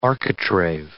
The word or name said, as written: architrave